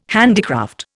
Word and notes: handicraft: Indian

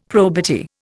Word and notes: probity: Indian

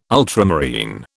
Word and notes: ultramarine: British English

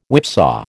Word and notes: whipsaw: American English

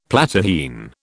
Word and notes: Platyrrhine: British English

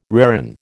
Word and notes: reran: American English

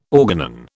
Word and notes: organon: British English

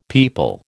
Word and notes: pipal: American English

